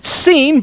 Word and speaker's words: Sin: They pronounced it Sin, but listen to how you spelled it. Ready? S-I-N.